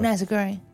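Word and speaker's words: nasi goreng: Hvordan udtales det? – Meget som du gjorde, tror jeg. Jeg tror ikke, jeg vil kaste mod at forsøge... – Nazi-goring? – Ja, det synes jeg er rigtig godt.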